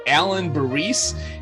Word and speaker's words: Alain Burrese: He has a very fancy French name, but it's simply Alan Barisse, and he served as a paratrooper in the U.S.